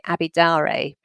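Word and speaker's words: Daré: In terms of a book, I think I'm going to recommend The Girl with the Louding Voice by Abby Daure. That's how you pronounce her surname.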